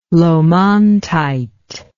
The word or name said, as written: laumontite